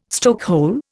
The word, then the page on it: stokehole: Indian